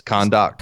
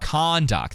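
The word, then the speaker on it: Kahndaq: How do you say this? – Kondok. – Kondok, thank you.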